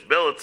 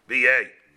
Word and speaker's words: billet: The Snan, Bolshes shenichdas liyir of a troop of soldiers billets themselves b'yay in the town. However you explain it, however you pronounce it, in English it's billet. It's really a French word b'yay.